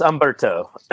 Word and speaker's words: Humberto: The H is silent. It's Humberto. – Humberto.